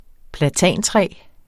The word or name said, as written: platantræ